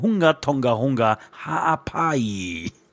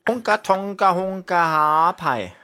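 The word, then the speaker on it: Hunga Tonga-Hunga Ha'apai: Und jedes Mal hat sie den Namen wirklich richtig korrekt ausgesprochen. Hunga Tonga Hunga Haapai. – Hunga Tonga Hunga Haapai.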